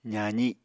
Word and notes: zazi: apatani

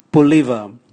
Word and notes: buliva: Raga

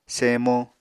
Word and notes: semo: Apatani

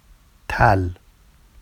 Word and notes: tal: Vafsi

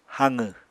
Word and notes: haqw: apatani

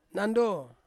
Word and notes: nando: Ansus

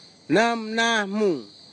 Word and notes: namnahmug: Kwamera

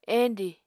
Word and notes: endi: Apatani

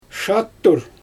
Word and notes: ʃattur: Xyzyl

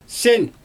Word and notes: sɪn: Xyzyl

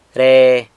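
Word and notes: re: San Lucas Quiaviní Zapotec

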